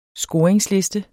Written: scoring

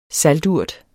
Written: salturt

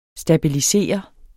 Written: stabilisere

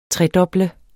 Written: tredoble

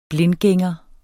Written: blindgænger

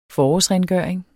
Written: forårsrengøring

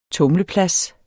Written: tumleplads

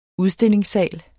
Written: udstillingssal

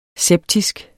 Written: septisk

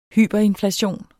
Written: hyperinflation

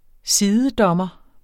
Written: sidedommer

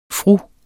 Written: fru

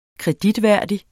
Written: kreditværdig

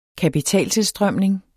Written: kapitaltilstrømning

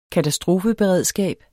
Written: katastrofeberedskab

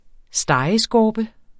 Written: stegeskorpe

